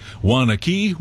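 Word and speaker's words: Waunakee: Wanaki, I always said that wrong and they corrected me. Wanaki. Michelle, we'll see you there.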